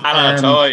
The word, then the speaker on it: Alaalatoa: I'm going to try and pronounce his second name, Alalatoa. Does that sound right? – Decent attempt, yeah. Close enough. Alalatoa, yeah.